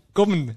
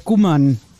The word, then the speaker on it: Gommen: Das heißt nämlich Gommen. – Gommen, ah ja, natürlich. Ich wollte es aber, ich habe gesagt, ihr versteht es nicht. – Gommen, nicht Gummern.